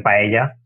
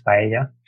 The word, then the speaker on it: paella: But the, for example, close to Barcelona in Valencia, or where I live, where I am now, is famous the paella, Spanish paella, or paella in English. – No, we can speak correctly today. If you go to Spain, you have to say it right, people.